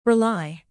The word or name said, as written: rely